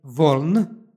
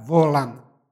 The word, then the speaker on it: voln: Stoga smo i ovdje pročitali voln, mada bi po tom pravilu moglo i da se izgovara volan. Ali, pošto je ovo imenica volna, onda je izgovor bez a.